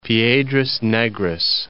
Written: Piedras Negras

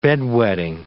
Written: bed-wetting

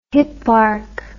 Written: hipparch

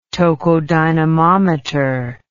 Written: tocodynamometer

